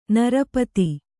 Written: ನಿರುಪಾಧಿ

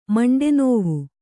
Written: ಮಂಡೆನೋವು

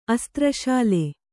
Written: ಅಸ್ತ್ರಶಾಲೆ

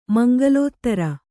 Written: ಮಂಗಲೋತ್ತರ

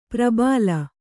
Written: ಪ್ರಬಲಿ

